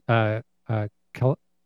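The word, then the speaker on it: Kalaloch: It's Kalaloc.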